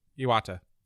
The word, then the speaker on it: Iwata: You pronounce it, Iwata.